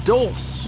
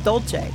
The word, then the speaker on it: Dolce: How do you pronounce that name? Dolce. I always thought it was Joe Dulce. No, it's Dolce.